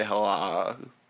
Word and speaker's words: Halak: He spelled it H-A-L. I think H-A-L-A-K. Halak. – Yeah, Halak. – But that's not how he said it. You say Halak. – Halak. – So it's like Kabbalah.